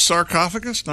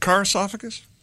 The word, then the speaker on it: sarcophagus: It's sarcophagus, not carcophagus? – It's sarcophagus, yeah.